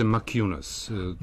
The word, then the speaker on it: Maciunas: Kako bi on trebalo da se izgovori? Maciunas. – Pa izgovaraju ga, u Americi čini mi se Maciunas.